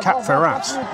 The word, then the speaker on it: Cap-Ferrat: For those people listening who can speak French, they might be recoiling at your pronunciation of Saint-Jean-Cap-Ferrat. One does not pronounce the T there.